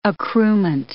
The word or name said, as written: accruement